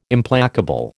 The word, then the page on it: Implacable: American English